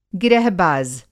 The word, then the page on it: गिरहबाज़: گِرہ باز